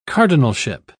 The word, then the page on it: cardinalship: British English